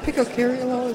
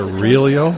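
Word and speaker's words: Picariello: Picacariolo, is that how you pronounce Picarilio? – It? Yeah, that's probably more like it.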